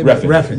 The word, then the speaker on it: Refn: Refn.